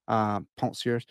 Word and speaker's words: Penseurs: I'll pronounce the second French word, but not the first one correctly. Penseurs.